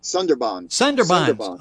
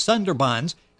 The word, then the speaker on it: Sandbarrens: Sandbarrens. – Okay, I got the accent in the wrong place. Sandbarrens.